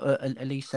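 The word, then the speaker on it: Olise: I was just going to, just before we move on, I was just going to mention Michael Elise, a championship player as well. Is that how you say his name? Elise from Crystal Palace. – Yes.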